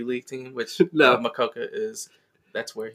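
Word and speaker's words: Mokoka: Mojita, Mojoka, Makuka. – Totally. – Figured out how to say it. – Just like the animal, the quokka, Adam Makoka.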